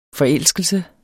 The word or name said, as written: forelskelse